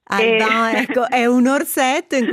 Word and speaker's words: Alban: Alban o Alban? Come lo pronunciamo? – Alban. Alban, perché Alban esiste realmente, è uno dei miei orsetti francesi.